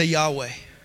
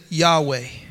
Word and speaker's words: Yahweh: The Hebrew word I am was four consonants, YHWH. Everybody say Yahweh. No vowels. They pronounced it Yahweh or Jehovah, which means Jesus has become my salvation.